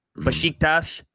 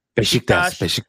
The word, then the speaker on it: Beşiktaş: We got to nail these pronunciations. I'm working on the team name. Beşiktaş. Beşiktaş. Because I fucked that up apparently on on the film session. I heard about it from our Turkish fans.